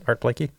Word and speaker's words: Art Blakey: So I was listening to some Art Blakey. – Is that how you pronounce it? Art Blakey? – Yeah.